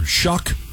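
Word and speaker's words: Shough: If I want to remember how to pronounce the last name of the New Orleans Saints quarterback, you say Tyler Shuck? – Correct. – They say just keep in mind it rhymes with cuck.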